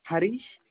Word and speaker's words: Harish: Harish. – Can you spell that for me, sir? – H-A-R-I-S-H.